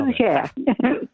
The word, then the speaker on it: gougères: Dori, I saw a recipe you had in the New York Times, and correct me if I'm pronouncing it wrong, gougères? – Very nicely done. – Nice. – Yes, gougères.